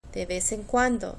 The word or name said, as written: de vez en cuando